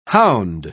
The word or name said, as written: hound